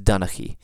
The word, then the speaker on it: Donnchaidh: So with Clan Duncan or Donnchaidh, you have this letter that pops up in the middle that doesn't necessarily need to be spelled into there the way Gaelic does it, but it will be pronounced Donnchaidh.